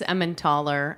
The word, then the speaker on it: Emmental: the Swiss Emmentaler, and then we have French Emmental. – Correct. – Am I saying that right? – That's correct.